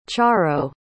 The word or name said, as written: charo